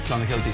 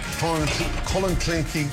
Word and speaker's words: Clonakilty: Irish tea bags or Clonakilty sausages from Cork. – Clonakilty. – Clonakilty.